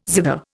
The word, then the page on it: zither: Indian